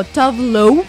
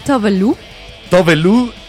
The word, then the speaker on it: Tove Lo: Y se la pronunciación correcta del nombre es Lo, aunque creo que varios en México la pronuncian como Tavlow.